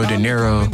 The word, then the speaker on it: Dinero: So I was trying to be something cool, like, hey, yo, it's Nero De Niro. It's spelled D-I-N-E-R-O.